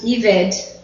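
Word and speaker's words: Eved (עבד): And I've written this in your notes, and the transliteration is Ebed, E-B-E-D, and it's actually pronounced Eved.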